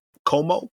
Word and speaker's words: Nkomo: She did a podcast with scholars Ella Bell Smith and Stella Como. It's spelled N-K-O-M-O, Stella N-K-O-M-O, about their seminal book, Our Separate Ways.